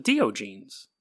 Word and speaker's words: Diogenes: And I had someone try to explain to me how you pronounce his name, but it's spelled Diogenes. And I'm Dio, like the rock band, and then genes. It's very obvious.